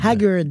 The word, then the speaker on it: Haggard: Haggard, je le prononce peut-être mal. – Merle Haggard. – Haggard, voilà.